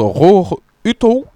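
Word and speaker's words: Roar Uthaug: Euh, Colprès de Roar, euh, enfin je sais pas comment ça se prononce, mais c'est Roar Hurtoff, euh, je crois. – Alors écoutez, je suis un peu un expert en Norvège, donc je vais dire que ça se prononce Roar Uto.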